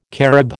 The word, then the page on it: Carib: American English